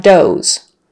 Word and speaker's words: does: If you have more than one female deer, you have does. One female deer is a doe. Two female deers, you would call it does. You would call them does. So, long O sound, and the Z on the end.